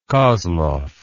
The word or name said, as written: kozlov